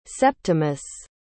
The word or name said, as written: Septimus